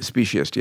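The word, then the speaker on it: speciesist: so pardon the butchering here. Speciest? – Speciest, yes. – Speciest, there you go.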